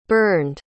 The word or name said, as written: burned